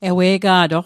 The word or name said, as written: ewe'ga'toq